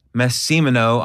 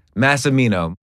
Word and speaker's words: Massimino: We've got Mike Massimino on the podcast. Did I say it right? – Nah, you screwed that up, man. – How do you say it? – Massimino.